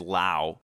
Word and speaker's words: Lowe: First name Nate, last name spelled the same way, but he pronounces it Low. Even though they both have the E on the end, they both...